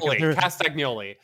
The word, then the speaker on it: Castagnoli: One Claudio Castiglione. – I'm glad you pronounced it. Castagnoli. Sorry. Castiglione is something different.